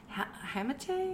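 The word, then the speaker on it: hamate: Matt Olson is out with a Hammett. – Is that how you say it? Yeah.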